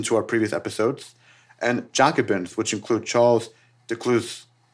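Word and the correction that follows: Delescluze: Delecluse, the better pronounced.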